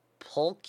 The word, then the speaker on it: pulque: P-U-L-Q-U-E. Pulque? – P-U-L-Q-U-E. Pulque. – Pulque. Well, I was saying pulque. It's another sweet fermented beverage made from that average sap. – Average? – A-G-A-V-E.